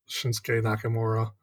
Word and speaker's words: Shinsuke Nakamura: I think they're just still in the water with Shinsuke Nakamura. I got a Japanese name I pronounced right. – You got it right.